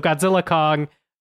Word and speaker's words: Godzilla x Kong: I'm told the X is silent. So Godzilla Kong, the new empire.